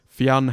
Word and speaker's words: Fionn: Aussie stadium announcers, Caribbean commentators, and the whole shebang in between have been saying his name as two syllables, and I'd love to hear one person just say Fionn properly.